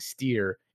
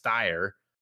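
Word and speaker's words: Styr: So they have a leader, the Magnar of Thenn, who right now is, and I was saying it wrong. I kept saying steer. It's Styre. Styre is their Magnar.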